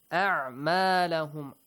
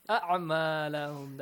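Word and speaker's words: أعمالهم: A'malahum. Fawad, make sure we have Hamza and Ayn, both of them. Do not mispronounce them.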